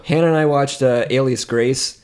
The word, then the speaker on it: Alias Grace: I always thought it was Elias Grace. – And I was like, God, I guess I don't know how words work.